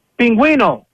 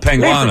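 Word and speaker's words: pingüino: First of all, I just want to say, I want to correct you in the pronunciation of pinguino. Penguin. In Spanish, it's pinguino. – Pinguino. – Listen to this. – Not penguano. Listen.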